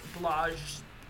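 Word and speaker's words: Błażej: And I don't know whether his name is pronounced Blase or Blage. – Okay. – B-L-A-Z-E-J. But it's pronounced Blage.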